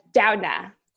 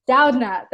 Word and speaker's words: Doudna: Doudna. – Doudna.